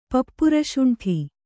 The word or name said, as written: ಪಪ್ಪುರಶುಂಠಿ